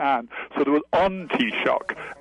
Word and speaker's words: Taoiseach: It's Taoiseach, people. So, you know, be a sport. Say it right instead of calling in the prime minister.